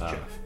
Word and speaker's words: Geoff: I think you said Joff. – Well, it's spelled Joff, but it's Geoff.